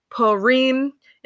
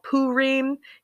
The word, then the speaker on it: Purim: Now, in the Western world, it's typically pronounced Purim. In the Eastern tradition, it is called Purim, and in some Central European countries, it's called Purim.